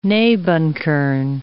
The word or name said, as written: nebenkern